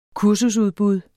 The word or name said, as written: kursusudbud